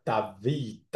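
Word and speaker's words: David (דוד): 那大卫在希伯来文的发音应该叫 David。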